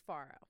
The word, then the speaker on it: Faro: I still don't know how to say it, but it's Faro.